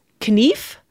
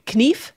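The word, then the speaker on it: knife: Knief, notice that I'm saying the K sound at the beginning, because that was actually pronounced in Old English. Knief, knievas.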